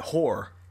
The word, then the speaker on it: Hoar: They call it Hoar. H-O-A-R. – Spell it again? – H-O-A-R.